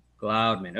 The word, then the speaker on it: Gloudeman: Is that how am I saying it correctly? – Gloudeman.